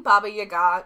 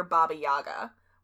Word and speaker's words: Baba Yaga: And I'm saying Baba Yaga because that's the way the Russians pronounce it. Often in America, you'll hear Baba Yaga, which is, like, not wrong. It's just the American pronunciation.